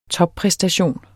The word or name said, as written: toppræstation